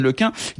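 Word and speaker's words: LeCun: il raconte Yann Le Quin, il y a un prix Nobel de physique américain qui s'est offusqué que je prononce mon nom en un car il pensait que c'était impossible en breton.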